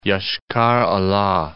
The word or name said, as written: Yoshkar-Ola